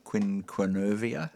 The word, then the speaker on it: quinquenervia: So it's Melaleuca quinquevera. – Quinquenervia. – Oh, quinquenervia. I always drop a few syllables.